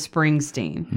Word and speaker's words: Springsteen: I said that wrong. I said Bringsteen. – Spruce Bringsteen? – That's what I said. I said Bruce Bringsteen instead of Springsteen.